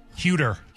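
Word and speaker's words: Huerter: Huter.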